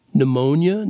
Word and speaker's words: pneumonia: And look, they both start with they both start with the pronunciation of n, pneumonia, mnemonic, but they one starts with a p and starts the other starts with an m, and yeah, it's all so weird.